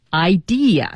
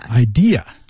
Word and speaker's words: idea: Idea. – Idea. – 千万不要读成 idea. – 别说 ID, 这个是那个护照什么身份证 ID. – 对，是 idea.